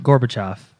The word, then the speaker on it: Gorbachev: Gorbachev.